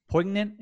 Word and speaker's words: poignant: It sounded like he had some pretty poignant. Is that the poignant? Do you pronounce the G? – Yeah, you do not pronounce that G.